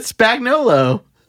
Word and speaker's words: Spagnolo: I feel like you mispronounced his name for sure. Like, there's no way that's how his name's pronounced. – Oh, yeah, that's it. Spagnolo.